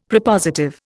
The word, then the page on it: Prepositive: Indian